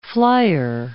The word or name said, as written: flier